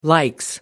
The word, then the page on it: likes: British English